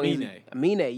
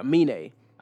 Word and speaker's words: Aminé: And yeah, so shout out to, I think I'm saying it right, Aminé. – You got the little thing over the E, so I think that's his name.